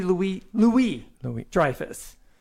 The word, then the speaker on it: Louis-Dreyfus: Julia Louis-Dreyfus. I don't think you pronounce the S.